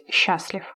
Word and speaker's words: счастлив: В настоящее время литературной нормой является ударение на первый слог, то есть «счастлив».